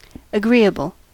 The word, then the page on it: agreeable: American English